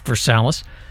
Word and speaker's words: Versailles: Then you go over to Versalles. That's where the or it's spelled Versailles, but it's pronounced Versalles.